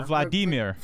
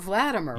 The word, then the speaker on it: Vladimir: But the weird thing is also now, he said, incidentally, that the first name is pronounced, this I never knew, Vladimir. – Oh, Vladimir. – Vladimir. – Rhyming with Redeemer, not Vladimir, yeah.